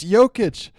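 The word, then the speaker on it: Jokić: He's as bad as Reggie Miller in terms of knowing his name, how to pronounce it, unless we're all wrong, but I don't think we are. It's Jokic.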